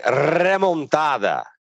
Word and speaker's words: remontada: Aliás, só um detalhe, eu adorei a ótima pronúncia do, do Juca em remontada, que é assim que se fala. É assim, é. é assim que é.